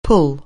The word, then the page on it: pull: anglais